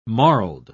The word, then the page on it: marled: British English